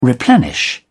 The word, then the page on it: replenish: British English